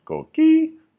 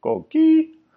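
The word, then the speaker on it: coquí: The word coqui is an excellent example because it also teaches us the pronunciation of the combination K, ki, that is also like a K in English.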